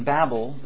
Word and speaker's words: Babel: Now, let me back up and say I recognize the correct pronunciation is Babel, but I've said Babel so long I will continue to say Babel.